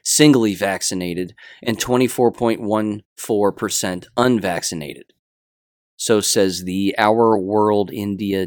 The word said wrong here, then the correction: Our World in Data: data. I'm saying that correctly. Our World in Data. There we go.